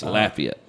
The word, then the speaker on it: Lafayette: I think I will probably end up going to Lafayette. – He's a great dude. – It's Lafayette. It's Lafayette. – Sorry, I pronounce it like the county in Mississippi.